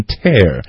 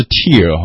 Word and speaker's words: tear: 对这个词的话，它如果做撕的时候，就动作的时候，它是念成 tear，不是 tear 哈，大家这里要注意一下。